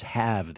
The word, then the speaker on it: halve: So the Rangers have the Yankees lead. Halve. That's how you spell that. You say have.